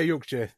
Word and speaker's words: Yorkshire: So would you say Yorkshire? Is that right? – Yeah, Yorkshire, yeah.